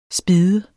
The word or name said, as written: spidde